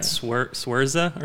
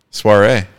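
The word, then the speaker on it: Saoirse: So I think most people would pronounce that Swerza or something. – Soiree. Ronan.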